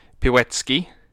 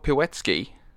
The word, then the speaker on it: Pilecki: It's spelled Pilecki, but yeah. I'm being reassured that it's pronounced... – I imagine the C is the S sound.